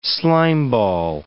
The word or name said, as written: slimeball